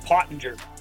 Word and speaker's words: Pottenger: And I'm going to help everybody with the pronunciation of my last name. – Okay. What is it? – German. – It's German. – Pottinger.